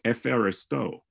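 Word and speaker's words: epheristo (ευχαριστώ): For example, the word Eucharistio I give thanks in modern Greek is pronounced epheristo.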